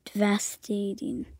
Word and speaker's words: devastating: Okay, this one is a long, long A, so it's A-ting. – Devastating.